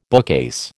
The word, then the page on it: bookcase: American English